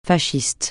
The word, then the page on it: fasciste: francais